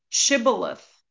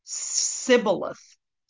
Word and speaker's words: Shibboleth: When he said, sorry, when he said, they said to him, "Then say Shibboleth." And he would say Sibboleth, for they could not pronounce it right.